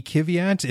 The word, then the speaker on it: Kvyat: Danny Kvyat in the AlphaTauri in fourth. No, I did not say that incorrectly.